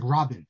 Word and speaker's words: Rabin: By the way, growing up, I always thought it was Rabin. And then I come to Israel. Everyone pronounces it Rabin. I'm not sure. Why isn't it Rabin? Isn't that the way Israelis are supposed to pronounce accent on the second half? Rabin is like the old Ashkenazic way of reading it. Rabin, Perez.